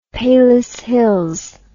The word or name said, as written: palos hills